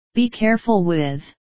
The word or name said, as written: be careful with